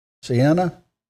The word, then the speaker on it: Siena: Somebody pronounce that. Siena? Yeah, that's right.